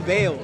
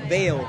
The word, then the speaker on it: Vael: I'm Bale. – How do you spell that? – V-A-E-L, Bale. – Ooh, I am Kurt. Nice to meet you. V-A-E-L. Ooh, that's cool. V-A-E-L, V-A-E-L.